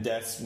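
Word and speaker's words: des (です): And desu means like, I guess that means it is... – Oh, right, because des ka means a question. – Right, des, and it's D-E-S-U, but you don't pronounce the U.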